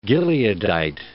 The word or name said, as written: Gileadite